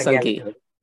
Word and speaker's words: Sanghi: And with Ashwin Sanghi, Sanghi or Sanghi? How do you pronounce it? – Yeah, I think Sanghi. Yeah. Ashwin Sanghi, yeah.